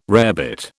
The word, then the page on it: rarebit: British English